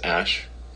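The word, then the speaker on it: Æ: I mean, it's just X, the letter X, and then the AE is like pronounced Ash.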